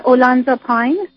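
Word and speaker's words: اولانزاپین: بعد من دوباره بردم پیش اون، این دکتر به من یه قرصی دادن، یعنی به پسرم یه دارویی داد به نام اولانزاپاین. – صحیح. – درست تلفظ میکنم؟ بله.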